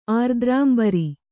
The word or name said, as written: ಆರ್ದ್ರಾಂಬರಿ